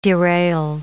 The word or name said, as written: derail